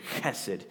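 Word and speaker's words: Hesed (חסד): The word love in this verse 5 of Psalm 100 is the Hebrew word hesed. And you're actually supposed to say it like with a that type of H. Hesed. You want to try that?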